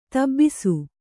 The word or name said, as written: ದೊಬ್ಬಿಸು